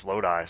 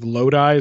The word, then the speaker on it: Lodi: Which I believe it's Lodi, I think. – Lodi? – That's the Creedence Clearwater Revival song. He says Lodi, so I'm assuming. – Okay, well, here we go. Mispronouncing all the names. – Unless John Fogerty is wrong. – John Fogerty is wrong about a lot of things. His pronunciation of Lodi is probably correct.